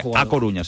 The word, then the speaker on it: A Coruña: Se calienta mucho el tema de Adama Traoré por el Deportivo de La Coruña. Este fichaje puede despertar muchas ilusiones en el Deportivo de A Coruña. – Ay, ay. – No lo he dicho bien. A Coruña.